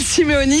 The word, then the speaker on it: Simeoni: Bonjour Gilles Simonet. Gilles Simeoni, je ne sais jamais s'il faut que je le prononce en corse.